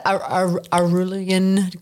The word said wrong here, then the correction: Araluan: goldfields in Victoria. – Araluan. – Araluan. Thank you.